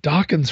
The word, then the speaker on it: Dokken: Dokken's from L.A., too. – Hmm. Rhymes with Rockin'.